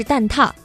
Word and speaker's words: 蛋挞: 要感谢一下胡小寿纠正了一下我的读音哈，我查了一下应该是蛋挞。